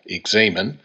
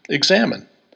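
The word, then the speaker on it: examen: Some pronounce it using a long A in the middle, examine, while others simply pronounce it like our English word, examine.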